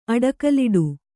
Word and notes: ಅಡಕಲಿಡು: aḍakaliḍu

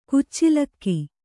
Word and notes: ಕಚ್ಚಲಿಗೆ: Kannada

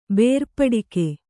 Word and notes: ಬೇರ್ಪಡಿಕೆ: kannada

